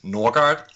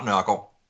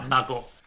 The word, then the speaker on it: Nørgaard: My last name is Norgard, if you try to pronounce it with an English accent. In Danish, it would be Lau Nørgaard. – Right. – Lau Nørgaard.